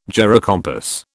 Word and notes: gyrocompass: British English

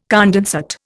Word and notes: condensate: Indian

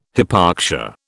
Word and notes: hypoxia: British English

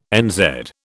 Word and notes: NZ: British English

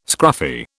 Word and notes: scruffy: British English

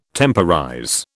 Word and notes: temporize: British English

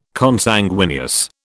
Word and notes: Consanguineous: British English

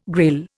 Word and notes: Grail: Indian

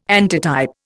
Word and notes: antitype: Indian

